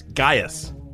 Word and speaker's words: Gaius: I think it's Gaius. That's how you say it.